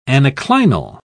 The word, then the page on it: anaclinal: British English